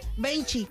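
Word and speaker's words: vinte: Benchi, como 20, pero allá en portugués.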